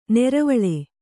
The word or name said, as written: ನೀರವಾಳಿ